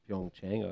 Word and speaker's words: Pyeongchang: Thanks for that pronunciation of Pyeongchang.